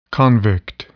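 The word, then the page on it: convict: noun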